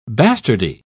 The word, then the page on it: bastardy: British English